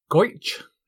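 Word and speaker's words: Gotye: Number one in the UK charts was Goitch, that's how you pronounce it, featuring Kimbra, with a tribute to ex-listeners of this podcast, somebody that I used to know.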